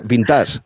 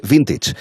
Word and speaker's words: vintage: O por ejemplo, no se dice vintage, se dice vintage.